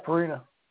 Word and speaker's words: Purina: Sponsored by Purina. Am I saying that right? – Yeah, Purina.